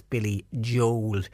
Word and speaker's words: Billy Joel: And another text in says, Patricia, I always thought that the way to pronounce Billy Joel's name was Billy Joel. Yeah, I used to say Billy Joel as well, but we have been told that his name is Billy Joel. So I think we've been wrong when we've been saying Billy Joel. So it's Billy Joel is how you pronounce it.